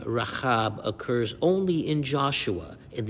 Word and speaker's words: Rahab: And I'm intentionally pronouncing the chet sound in Rahab when referring to the harlot in Joshua in order to note this distinction.